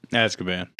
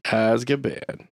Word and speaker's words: Azkaban: Azkaban. – Azkaban.